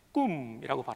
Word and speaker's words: cum: 영어로는 COM이지만 라틴어는 CUM, 꿈이라고 발음하죠.